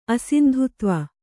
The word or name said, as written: ಅಸಿಂಧುತ್ವ